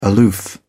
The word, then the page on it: aloof: British English